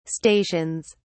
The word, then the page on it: stations: British English